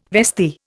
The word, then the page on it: vestee: Indian